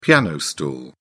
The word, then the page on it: piano stool: British English